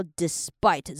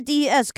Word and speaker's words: despite: 这一句的 this 的时候，我的舌头是贴在这边的。那前面的前面三个的重点就是 but 是促音，despite 后面那边也是促音，再接 this 或者是 this，就是看你们自己念的怎样比较舒服。那还是有个点要强调，我们看到 despite 这边它是 dis dis，它念起来会比较像 dis 而不是 des，因为 des 可能有些人会想要把它念成 des， OK，不是哈，不是 despacito，这边是 despite，所以呢是短短的 dis。